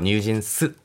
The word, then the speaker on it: NewJeans: 韓国ではニュージーンスと発音するんです